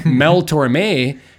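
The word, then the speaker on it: Tormé: You had mentioned when we were discussing it that Mel Torms... – Okay, so I said his last name wrong. Shoot me. – I'm sorry. I just had to bring that up. Mel Torme has a song called Bernie's Tune.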